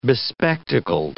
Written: bespectacled

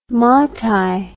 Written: matai

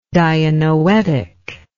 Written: dianoetically

